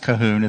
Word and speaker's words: Colquhoun: And just because it's pronounced Cahoon, it's not spelled that way. It's C-O-L-Q-U-H-O-U-N. And again, for years, I pronounced it as Colquhoun, and it's actually Cahoon. And John Cahoon has a book called On Repentance, True and False Repentance is the name of the book.